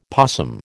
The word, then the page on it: possum: American English